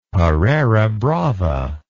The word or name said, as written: pareira brava